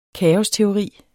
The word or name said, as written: kaosteori